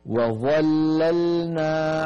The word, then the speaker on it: وظللنا: أيضا في الآية 160: وظللنا عليهم. ننتبه إلى هذه الكلمة، فيها حرف واحد مفخم وهو الظاء، أما الواو التي قبل الظاء مرققة، وظا وظا، ثم بعد الظاء المفخمة نعود إلى اللامات المرققة، المرققة أيضا.